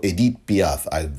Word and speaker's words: Edith Piaf: Marion Cotillard from France, who played Edith Piaf. Or Edith Piaf, the proper pronunciation of the French legend.